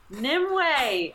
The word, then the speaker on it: Nimue: Nimue! I was like, how do you say this word? Because it's N-I-M-U-E.